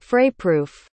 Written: frayproof

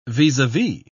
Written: vis-a-vis

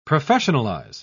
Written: professionalize

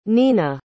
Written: neena

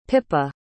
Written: pippa

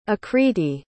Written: akriti

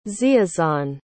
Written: ziazan

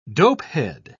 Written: dopehead

